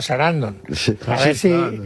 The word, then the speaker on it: Sarandon: Susan Sarandon.